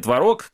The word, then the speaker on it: творог: Эксперты Росконтроля проверили 9% творог, который, кстати, знаешь, что творог и творог, это же слова-варианты, они тебе очень нравятся. Но ни в том,